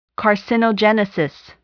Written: carcinogenesis

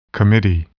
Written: committee

